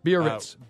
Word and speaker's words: Biarritz: Biarritz.